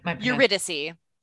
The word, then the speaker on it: Eurydice: Eurydice.